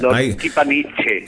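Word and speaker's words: Lordkipanidze: Derby Lorkipanandice. – Lorkipaniche. Escribe Z, pero se pronuncia Che.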